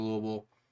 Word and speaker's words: Louisville: you want to go to the kickoff to Derby Festival, Thunder over Louisville. Yes, that's how you say it.